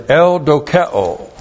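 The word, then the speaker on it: Eudokeo (εὐδοκέω): But there is a third word in the Greek etymology, and that is eudokeo. Looks like this in the Greek. E-U-D-O-K-E, long O. Eudokeo.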